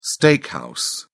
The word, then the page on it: steakhouse: British English